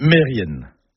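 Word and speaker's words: merienn: Et puis un autre mot, par contre, qui lui n'est jamais bien prononcé, c'est Mérienne. Mérienne, comme ça, ça ne vous parle pas. Mais si je dis Mérien, bah oui, vous allez reconnaître le nom de famille, Kermérien, le nom de lieu. Eh ben non, tout ça c'est faux, c'est Mérienne, Kermérienne.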